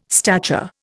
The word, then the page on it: stature: Indian